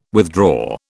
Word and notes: withdraw: British English

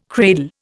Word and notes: cradle: Indian

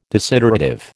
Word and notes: desiderative: American English

